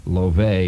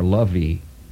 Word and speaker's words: love: It's L-O-V-E. There's an E on the end. And that throws many of you, I know, in this age of advancing illiteracy, but that E is silent. It's not love or lovey. It's love.